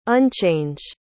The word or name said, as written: unchange